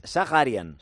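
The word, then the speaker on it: Zakharyan: Cómo se pronuncia su apellido y es Zaharian. Este es la pronunciación correcta.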